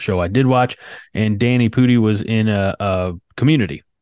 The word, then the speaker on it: Danny Pudi: And Mythic Quest is, it stars Rob McElhenney and Danny Pudi.